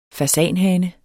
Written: fasanhane

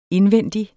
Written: indvendig

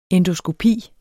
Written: ENDOSKOPIEN

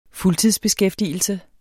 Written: fuldtidsbeskæftigelse

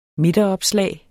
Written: midteropslag

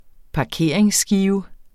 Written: parkeringsskive

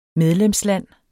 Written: medlemsland

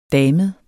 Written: damet

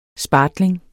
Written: spartling

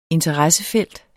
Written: INTERESSEN

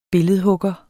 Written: billedhugger

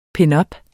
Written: pinups

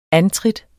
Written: antrit

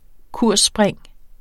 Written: kursspring

